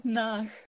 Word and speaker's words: nach: Um, vor, vor uh, and nach.